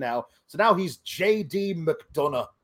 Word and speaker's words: McDonagh: Because you've got to say it like McDonagh. Because there's there's the, the, the GH there's there's a lot of nice roughage in in the in the breathiness of the Irish uh, of the Irish sound.